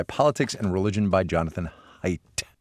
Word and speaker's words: Haidt: Thomas Crowe is the psychology graduate student who wrote to us and said, listen, you're pronouncing Jonathan Haidt's name wrong, because we had been saying Jonathan Haidt. Jonathan Haidt, H-A-I-D-T. It is actually Haidt.